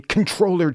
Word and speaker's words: Comptroller: David Walker, as nominated by Walter Jones in 2013, is the former Comptroller General of the United States, or if you want to pronounce it technically correctly, though not phonetically, Controller General.